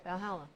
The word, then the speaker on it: Valhalla: Valhalla.